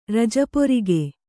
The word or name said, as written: ರಜಪೊರಿಗೆ